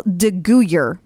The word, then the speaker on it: DeGooyer: So they talk to, let's see, Paul DeGuyer, which I don't know that I'm saying that correctly. It's Goo, like G-O-O, so I'm sorry if I'm not saying his name correctly.